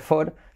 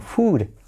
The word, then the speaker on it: food: Y otra palabra que se escribe con doble O como comida no va a ser for, sino food. Alright, A-E-I-O-U, una U española.